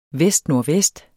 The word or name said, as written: vestnordvest